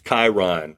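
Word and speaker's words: Kyren: Kieran, oh. Kyron. – It's Kyron. – Kyron. – I don't know where you came up with Kieran.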